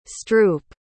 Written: stroup

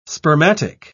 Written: spermatic

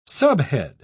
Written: subhead

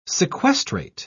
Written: sequestrate

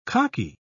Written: khaki

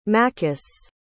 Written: makis

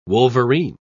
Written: wolverine

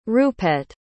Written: rupette

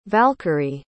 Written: valkyrie

